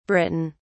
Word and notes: Britain: British English

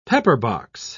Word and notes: pepperbox: British English

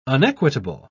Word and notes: unequitable: British English

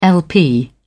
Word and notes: lp: British English

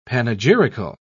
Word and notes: panegyrical: British English

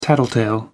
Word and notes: tattletale: American English

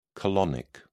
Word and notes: colonic: British English

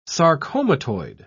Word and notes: sarcomatoid: British English

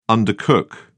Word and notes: undercook: British English

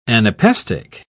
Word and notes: anapaestic: British English

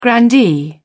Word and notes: grandee: British English

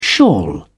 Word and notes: shawl: British English

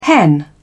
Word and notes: hen: British English

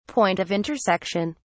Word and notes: point of intersection: British English

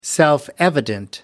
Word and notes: self evident: American English

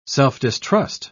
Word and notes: self-distrust: British English

